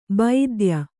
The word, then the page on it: ಭಿದ್ಯ: Kannada